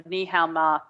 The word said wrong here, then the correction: NaNoWriMo: thing that's been going in November. – NaNoWriMo for those people who are listening.